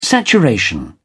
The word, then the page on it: saturation: British English